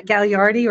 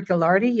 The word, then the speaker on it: Gagliardi: yeah, Gagliardi or Gallardi, yeah, you nailed it.